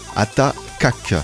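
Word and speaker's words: Ata Kak: Et dans le Smile, on verra notamment Clément Atakak, c'est bien ça, je prononce bien c'est bien comme ça que tu peux le prononcer, pas de souci.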